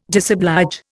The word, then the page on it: disoblige: Indian